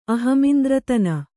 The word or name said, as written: ಅಹಮಿಂದ್ರತನ